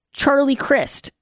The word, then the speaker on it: Charlie Crist: And we'll talk about Charlie Crist from Florida.